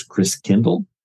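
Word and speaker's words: Christkindl: And so Martin Luther cleans that out and says all the gifts come from the Christ child the German pronunciation of Christ child is Chris Kindle, which is where you get the word uh, care, kindergarten.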